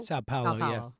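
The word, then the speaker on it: São Paulo: Today's question, do you like the Eagles opening their 2024 regular season on a Friday night in, how do you say this, Sao Paulo, Brazil? – Sao Paulo.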